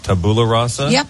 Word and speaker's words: tabula rasa: You mentioned the phrase tabula rasa. Saying that correctly? – Yep.